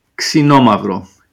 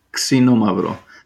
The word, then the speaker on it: Xinomavro: Xinomavro. Xinomavro. Like a K-S.